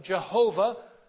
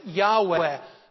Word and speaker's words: Yahweh: The old way to translate this name was Jehovah. The more modern way is to pronounce it Yahweh.